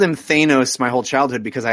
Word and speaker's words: Thanos: And it has a bad guy called Thanos, who I call Thanos, which I know is wrong. – Yeah, it's Thanos, like Van. – Right. It's a short. It's not a long A.